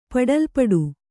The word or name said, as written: ಪಡಲ್ಪಡು